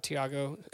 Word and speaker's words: Tiago: But uh, Tiago Almada, Thiago, Tiago, sorry, uh, Tiago. yeah. For the pronunciation,